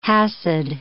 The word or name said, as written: Hasid